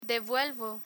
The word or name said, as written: devuelvo